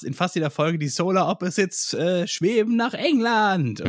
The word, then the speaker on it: Solar Opposites: Ja, Op Solar Opposites. Opposites. So spricht man das aus.